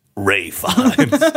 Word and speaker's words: Fiennes: Now, I don't know a lot, but I do know it is pronounced Ray Fiennes.